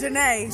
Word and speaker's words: Janae: My name is Janae. – Janae. – You know it rhymes. While Shamel is away.